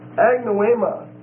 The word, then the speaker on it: agnoema: The next word is agnoema. A-G-N-O-E-M-A. A-G-N-O-E-M-A. It's a long E.